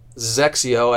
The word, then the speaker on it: XXIO: XXIO and pronounced Zexio. I always say sexy with a Z, Zexio.